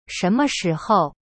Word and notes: 什么时候: shenme shihou

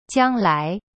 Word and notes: 将来: jiang lai

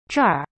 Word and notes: 这儿: zhe-r

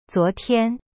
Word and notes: 昨天: zuo tian

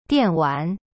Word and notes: 电玩: dian wan

